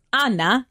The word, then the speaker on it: Anna: So my name is Anna, but my real name is Anna because I come from Italian parents.